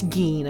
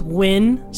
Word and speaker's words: Nguyen: So I checked on Reddit, under Reddit serial killers, and the top comment was, here it's pronounced Win, spelling it Nguyen.